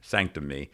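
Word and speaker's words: SNCTM: There's a sex club called Sanctum, but it's spelled without any of the vowels. It's S-N-C-T-M. – Like a tech company. – Yes, yeah, Sanctum. – .ly. – Sanctum.me.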